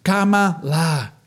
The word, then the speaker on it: Kamala: Y así como de payasito de circo, los de aquí dicen una cosa y los de acá dicen otra, empiezan a decir, se pronuncia como coma, cama, y luego la, como la, la, la, y canta la niñita, y entonces empiezan, cama, la, cama, la, y se hace como todo un eco.